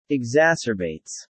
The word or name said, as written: exacerbates